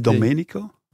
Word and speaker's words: Domenico: Donderdag maakte Domenico Tedesco zijn selectie voor de oefening. – Is het niet Domenico? – Wat zei je? – Domenico. – Domenico. – Ah ja, mijn uitspraak is soms nog...